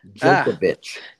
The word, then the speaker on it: Djokovic: With hopefully Djokovic, however you say his name. – Djokovic. There is no D sound. Djokovic.